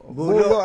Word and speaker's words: Bolo: Pero pag binago mo yung spelling, B-O-L-O, Bulo yun. – Naging itak yun. – Itak na yun. – Ito pala, Bulo. Bulo ang pronunciation, ano?